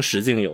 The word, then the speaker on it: 实境: 替代现实游戏，如果你去百度百科的话，你可能会发现它叫平行实境游戏，或者叫侵入式现实互动游戏。 – 哎，注意啊，它这个实境啊，不是我们发音有问题，不是实景啊，它就是实际的实，境界的境啊，平行实境游戏。